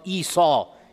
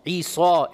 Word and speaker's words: Isa: This is a Latinized transliteration of Isa. Isa is most likely how he pronounced it in Aramaic, and in Arabic, Isa.